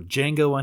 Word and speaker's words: Django: The first one is for Django Unchained. The D is silent.